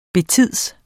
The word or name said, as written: betids